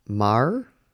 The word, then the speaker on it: Maher: It is Brett Maher.